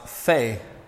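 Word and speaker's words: pe (פ): Pay can also be pronounced Fe in Hebrew. Sometimes pe is a hard p, and other times in Hebrew it's a f sound.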